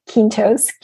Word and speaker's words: Quintos: So my name is spelled Nori, N-O-R-I-E, Quintos, Q-U-I-N-T-O-S.